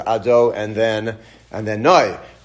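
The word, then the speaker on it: Ado and then Noi (אדני): We pronounce it as Ado and then Noi. But that's how we say.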